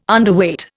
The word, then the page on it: Underweight: Indian